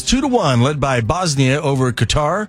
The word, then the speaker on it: Qatar: I think you pronounce it a few different ways, but the fans that I talk to pronounce it Qatar, which is closer to the Arabic.